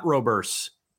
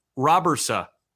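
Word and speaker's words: Robberse: I've got the pronunciation, according to Baseball Reference. Sem Robersa. It's not Roberse. Robersa. Sem Robersa, right-hander for the Toronto Blue Jays, 21-year-old from Zeist in the Netherlands.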